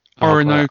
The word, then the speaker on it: Orinoco: Oronico, is that how you say it? – Orinoco. Oh, well, I'm not going to keep embarrassing myself. – Yeah, it's Orinoco.